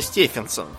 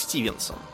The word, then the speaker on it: Стефенсон: Я так понял, что он именно Стефенсон, при том, что эта фамилия часто читается как Стивенсон. Но этот вроде как Стефенсон.